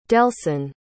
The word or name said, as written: delsin